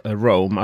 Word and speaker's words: Roam: Och inte vilken Sonos som helst, utan en Sonos Roam. Är det så man uttalar det på svensk engelska? – Jag vet inte, jag hör bara Rome, alltså staden Rome.